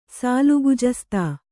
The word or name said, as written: ಸಾಲುಗುಜಸ್ತಾ